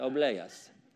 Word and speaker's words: obleas: Obleas. You got to say it like that. Obleas.